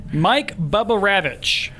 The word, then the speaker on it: Bugarewicz: Mike Bugarewicz. Is that right? – Yes.